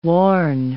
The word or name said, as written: worn